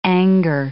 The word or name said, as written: anger